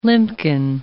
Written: limpkin

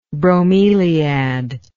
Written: bromeliaceous

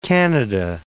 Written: Canada

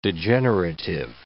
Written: degenerative

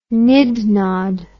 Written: nid nod

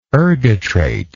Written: ergotrate

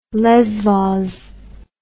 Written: lesvos

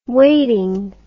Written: weighting

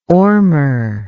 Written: ormer

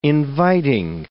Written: inviting

